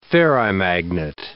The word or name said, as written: ferrimagnet